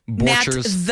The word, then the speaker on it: Borchers: All right, big thanks to Nat Borchers. Am I saying that right? – Borchers.